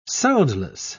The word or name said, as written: soundless